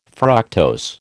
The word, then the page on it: fructose: American English